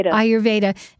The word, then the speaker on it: Ayurveda: She's author of the groundbreaking new book, Healing the Thyroid with Ayurveda. Did I say that right? – Ayurveda.